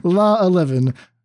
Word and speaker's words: LAA11: how do we pronounce this? It's La 11. So La 11, who's in the 5-0s with a Yorian version of a Dimir Alteration deck.